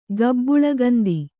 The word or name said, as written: ಗಬ್ಬುಳಗಂದಿ